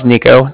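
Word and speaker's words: Niko: Again, my name is Nico. That's N-I-K-O.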